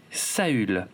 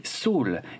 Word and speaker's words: Saül: Au fait, vous savez comment s'appelait le premier roi d'Israël selon la Bible hébraïque il s'appelait Saül, qu'on peut également prononcer Saul, exactement comme le prénom du colonel Ty dans la série réimaginée, dont le nom complet est donc Saul Tai.